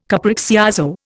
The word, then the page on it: capriccioso: Indian